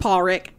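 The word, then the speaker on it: Pádraic: God, so I look it up to figure out how to say it, and it's just Pawrick.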